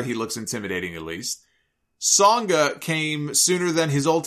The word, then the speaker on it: Sanga: Grayson Waller's bodyguard, I guess it's Songa and not Sanga, even though it's spelled that way.